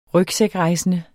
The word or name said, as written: rygsækrejsende